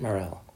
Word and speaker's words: Morelle: My name is Matt Morrell. – Could you please spell that, Mr. Morrell? – Of course. It's M-A-T-T, then M-O-R-E-L-L-E.